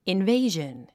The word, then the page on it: invasion: invéiʒən; インベイジョン